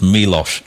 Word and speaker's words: Milos: Just had an email in from Leslie saying, "Hello, Dave, at the risk of being a nerd, I've heard everyone mispronounce Milos Forman all day. The correct pronunciation is Milos." Okay, so there we are, Milos Forman.